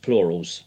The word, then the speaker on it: PLRLS: I read them as PLRLS, but I definitely know they're pronounced plurals. Is that correct? – Yeah. – You got it.